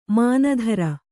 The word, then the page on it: ಮನತರು: kannada